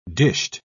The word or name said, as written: dished